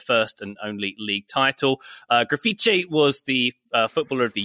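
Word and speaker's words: Grafite: I'll go with Graffiti. I think that's how you say his name. Like Graffiti. – Yeah. – Graffiti, Brazilian striker who made his name with Wolfsburg in 2008-09.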